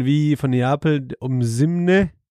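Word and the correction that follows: Osimhen: Ja, Osimen.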